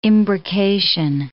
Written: imbrication

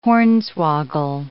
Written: hornswoggle

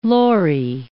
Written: lory

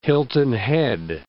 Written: Hilton Head Island

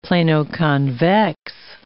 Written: plano-convex

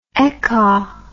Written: ekka

